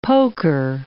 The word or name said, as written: poker